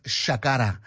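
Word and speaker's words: Ṣakara: So, but it's written S, like, you'd say sak, it's written saka, Sakara, but pronounced Shakara.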